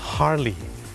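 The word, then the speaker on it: Harley: ฮาร์ลีย์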